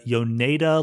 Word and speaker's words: Yoneda: You bring up this thing called the Yoneda Lemma.